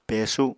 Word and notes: pesu: Apatani

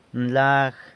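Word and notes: nlag: San Lucas Quiaviní Zapotec

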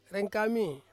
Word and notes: rengkami: Ansus

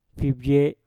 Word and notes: fibwe: Mpur

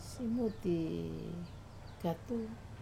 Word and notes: simuti katu: Wamesa

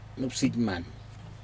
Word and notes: nupsijman: Aneityum

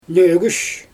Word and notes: nögüsh: Xyzyl

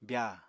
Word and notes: byaa: apatani

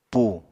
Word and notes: pù: apatani

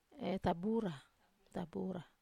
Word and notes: tabura: Wamesa

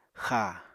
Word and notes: xáa: Apatani

